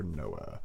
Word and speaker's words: Noah: No one's excited except for Noah. – No one cares. – Okay, so I'm just... – Is that why my name's Noah? Because it just rhymes with no? – Yeah, maybe.